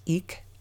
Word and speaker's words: Ik: This is a day called Tu'ik, I-K, apostrophe, called pronounced Eek, called Eek, a dynamic energy associated with wind, breath, and spirit.